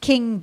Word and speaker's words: Keng: It's pronounced King, but written K-E-N-G.